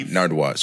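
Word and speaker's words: Nardwuar: Nard, Nardwa.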